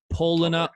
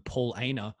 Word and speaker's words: Paulaner: I think Paulaner sounds better than Paul Aner. Paulaner is the correct pronunciation, but Paulaner, I don't know.